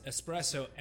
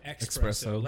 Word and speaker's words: espresso: Because some people pronounce espresso, expresso.